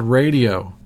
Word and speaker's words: Raydio: This next song is by a band called Radio. That's spelled R-A-Y-D-I-O.